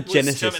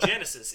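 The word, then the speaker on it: Genisys: No, it's not Genesis. It's Genesis. You got to say it properly.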